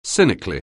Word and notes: cynically: anglais

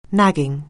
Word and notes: nagging: anglais

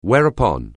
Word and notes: whereupon: anglais

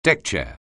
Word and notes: deckchair: anglais

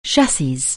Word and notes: chassis: anglais

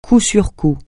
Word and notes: coup sur coup: French

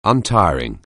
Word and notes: untiring: anglais

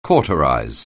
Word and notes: cauterize: anglais

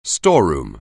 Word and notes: storeroom: anglais

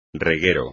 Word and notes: reguero: espagnol

